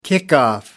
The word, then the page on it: kickoff: British English